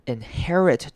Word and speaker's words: inherit: 今天第一个重点是呢，inherit 这个字，inherit 注意到的是它的 e，这边不会念成 e 的声音，而是要念成 a 的声音，inherit 会念成一个长母音 a，inherit。